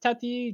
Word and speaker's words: Tati: Uh, Willow's voice actor is, I believe her first name is pronounced Tati Gabrielle.